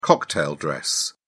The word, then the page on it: cocktail dress: British English